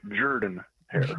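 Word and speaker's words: Jordan-Hare: Actually, let's work on your pronunciation here. It is Jordan Hare. – Jordan? Jordan? – That is how it is pronounced.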